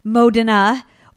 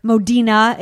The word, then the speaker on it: Modena: So it is said that the first written reference to balsamic vinegar was actually made in 1046 from the area that is now Modena, or Modena, it's pronounced a couple of different ways, in Italy, of course.